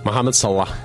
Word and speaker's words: Mohamed Salah: Mohamed Salah.